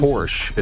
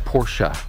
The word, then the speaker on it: Porsche: Porsche. It's Porsche, for instance.